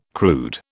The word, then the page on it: crude: British English